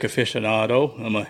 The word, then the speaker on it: aficionado: Like at the time, Dale, I know you're a music aficionado. Did I say that right? Aficionado? – Yeah.